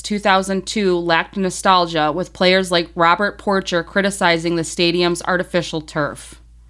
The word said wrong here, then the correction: Porcher: Oh, Robert Porsche. – Oh, Porsche. My bad. – Yeah, that's how it's pronounced.